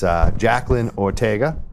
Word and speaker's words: Jacqueline Ortega: It's Jacqueline Ortega.